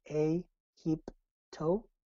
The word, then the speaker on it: A-hip-to: The mantra is A-hip-to, pronounced in 3 separate syllables.